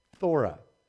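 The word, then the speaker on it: phthora: The word is for corruption is thora, you know, with a P-H-T-H-O-R-A, thora.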